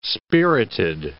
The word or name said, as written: spirited